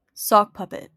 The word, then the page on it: sock puppet: Southern England; /ˈsɒkˌpʌpɪt/; American English; /ˈsɑkˌpʌpɪt/; RP; NZ; [ˈsɔ̟kˌpɐpət]; /ˈsɒkˌpɐpət/; GA; dialects of Canada; dialects of the US